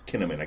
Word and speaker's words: Kinnaman: It's Kinnaman.